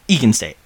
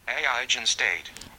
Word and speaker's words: eigenstate: Eigen State? Egan State. I'm going to guess Egan State. – Aigen State. – Aigen State? – Aigen State. – Aigen State. Okay, that's what I'll go with.